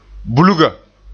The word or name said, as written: bulga